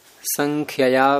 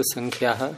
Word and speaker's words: संख्यया: बहुव्रीहि समास का प्रकरण चल रहा था, उसमें अगला सूत्र है संख्यया व्यया सन्ना दूराधिक संख्या संख्येये। इसमें उच्चारण करते समय बहुत से लोग संख्या बोल देते हैं प्रारंभ में, संख्या तो अंत में वैसे आ रहा है, यह है संख्यया तृतीय के वचनांतम।